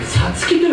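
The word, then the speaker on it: 五月: 月と書いて「さつき」と読む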